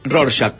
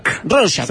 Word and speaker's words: Rorschach: Rorschach. ¿Cómo, yes, ¿cómo se dice? – Rorschach. – Rorschach.